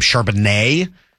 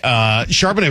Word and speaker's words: Charbonnet: Whoever it was that was calling that game kept calling him Charbonnet, which was driving me nuts watching the replay of it last night. But whatever. Like, it just, you got the emphasis on the wrong syllable.